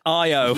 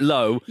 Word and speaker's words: Io: I do like the fact you made that joke, because they do actually, within the text, they even go, "pronounced IO." – Yeah. Just in case. – Who's this text for? This is literally, this is for you, the audience. Just don't call it low.